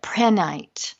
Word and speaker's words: prehnite: And this crystal is called prehnite, and it's spelled P-R-E-H-N-I-T-E. Some people call it prehnite. I refer to it as prehnite. I've heard it said both ways.